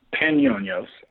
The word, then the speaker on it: Panionios: Panionios Athens. How did that come about? – Panionios.